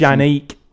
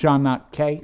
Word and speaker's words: Chaneque: Chanique, uh, whatever. C H A N E Q U E if any of our Spanish speaking listeners. – Shanake.